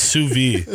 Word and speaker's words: sous vide: I think in French it's pronounced sous vide.